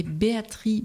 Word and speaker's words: Béatrix: et Béatrice Beck, alors ça s'écrit Béatrix, mais on ne dit pas le X.